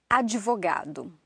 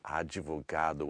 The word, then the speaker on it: advogado: Advogado. – Advogado.